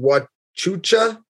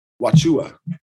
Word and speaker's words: Wauchula: So you're in Tampa and it's called Wachucha. – Wachua. – Wachua. Let's do this. – It is spelled Wauchula.